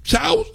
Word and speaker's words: Shaw: Chow se pronuncia S-H-A-W, Avenida, en el 605, ¿eh?